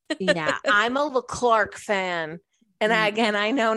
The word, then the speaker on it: Leclerc: Leclerc, but it's spelled like Leclerc.